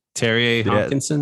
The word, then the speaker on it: Terje Haakonsen: Oh, Terje, Terje Haakonsen.